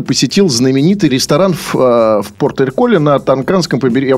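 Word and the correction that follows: Toscana: Я правильно произношу? На Тосканском.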